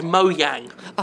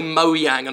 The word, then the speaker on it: Mojang: She just, like, whispers to Phil Spencer before she comes out, I'm going to say Mojang and fuck them up. – Well, in Scandinavia, J is pronounced like our Y in Scandinavia.